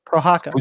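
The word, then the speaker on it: Procházka: Prochazka.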